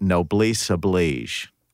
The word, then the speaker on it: noblesse oblige: I think that they often, when you are wealthy, there's this, in England, you call it noblesse oblige. It looks like oblige noble, if you look the way it's spelled with some accent marks, but it's called a noblesse oblige.